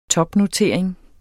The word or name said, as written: topnotering